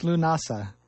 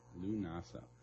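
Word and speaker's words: Lúnasa: How do you pronounce that on Thursday? – Oh, yeah. On Thursday, it's Lunasa. – Lunasa.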